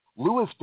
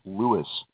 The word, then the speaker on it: Lewes: They spell it L-E-W-E-S. Some people who are not familiar with it will look at the map and say, oh, you must live in Lewis. Well, no, it's Lewis. Oh, L-E-W-I-S? No, L-E-W...